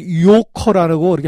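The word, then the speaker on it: 요커라고 (游客): 그러니까 그 여행 오는 사람을 손님을 유객을 중국 발음으로 요커라고 이렇게 발음합니다.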